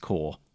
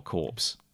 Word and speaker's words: Corps: that's meant to be pronounced Corps and not Corpse.